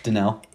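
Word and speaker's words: Danielle: Him and Danelle Hunter, however you pronounce that name, is Danelle. – Yeah, it's Danelle.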